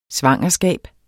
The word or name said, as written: svangerskab